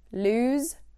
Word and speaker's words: lose: Lose, loose. Loose means something is not tight. It's an adjective. Lose is the verb that we're going to be talking about today. So be wary of the pronunciation and be very wary of the spelling. Lose, not loose.